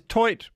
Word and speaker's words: toit: So does toit mean roof? – It's pronounced toi.